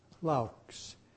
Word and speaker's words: Laux: You should have received these aux pages from, I believe the name is pronounced Laux, L-A-U-X.